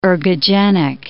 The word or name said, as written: ergogenic